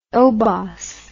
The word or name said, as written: oubaas